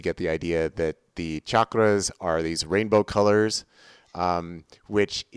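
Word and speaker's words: chakra: Everybody always says chakras, but they're chakras, if you're actually going to use the Sanskrit word.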